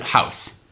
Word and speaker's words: Haus: So you pronounce it House. H-A-U-S? – Yeah, I believe it's German.